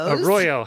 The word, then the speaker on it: arroyo: Arroyo, how quickly they fill up.